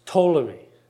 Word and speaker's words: Ptolemy: I know it says with a P, but in Greek, you don't pronounce the P, okay? It's Ptolemy.